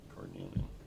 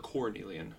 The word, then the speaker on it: carnelian: Carnelian. – Is it Car or Cornelian? – Car.